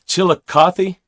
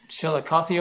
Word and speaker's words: Chillicothe: Chillicothe? Yeah. – Chillicothe, Ohio. – Chillicothe, Ohio.